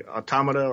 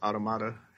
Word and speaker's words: Automata: You can either say automata or automata, either way.